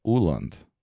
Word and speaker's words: Åland: which is from Stockholm to Åland, which is it's an A with a circle on top of it. Not sure what that's called. And then an L-A-N-D, and it actually has a silent S.